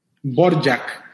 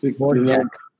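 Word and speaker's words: Dvořák: Dvorak. – Dvorak. – Quizás se pronuncie diferente, ¿no? Pero normalmente en Occidente, sí, o acá en este lado del mundo, les decimos Dvorak.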